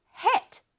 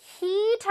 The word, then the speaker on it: hit: 근데 이거 발음할 때 heat 하면 큰일 나요. heat 하면 열기, 그 뜨거운 열기를 뜻합니다. 이걸 발음할 때는 hit, hit, 오케이, 할 수 있겠어요? 시작, hit, alright, 굉장히 짧고 아주 강렬하게 hit, 오케이, 치다, 때리다라는 뜻이에요.